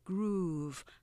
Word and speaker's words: groove: 마찬가지로 요새 그루브 그루브 그루브를 타 그렇게 힙합이나 여기서 많이 그렇게 표현을 하지만 이것도 이름절이에요. groove 하나로 길게